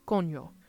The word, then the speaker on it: coño: The next word and final grammar lesson is coño, which is spelled C-O-N-O. The N has a tilde over it, which is the little squiggly line. And it changes not just the sound of the N from cono to coño,